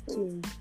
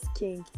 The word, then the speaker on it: Qing: Q-I-N-G is pronounced as King. It's not hard. – Queen. – Guys, listen to me, your uncle. It is pronounced as King, Lara, and every other person.